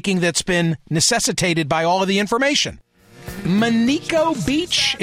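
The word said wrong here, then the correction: Manitou Beach: is that how you pronounce it? Gilly, welcome. You're in Michigan. Monico Beach. Where's Monico Beach? – Manitow Beach.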